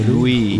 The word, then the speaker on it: Lewis: Y solo Larry Lewis, ¿cómo lo pronuncio? – Lewis. – Larry Lewis. – ¿No has visto Fórmula 1? Como Lewis Hamilton.